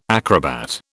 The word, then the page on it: acrobat: British English